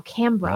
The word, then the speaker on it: Canberra: Big wave to both of you from Canberra. Canberra. Which you Canberra. – Early pronounced. Oh, Canberra. Ah. Which you early pronounced almost correctly. Canberra.